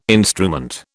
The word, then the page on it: instrument: British English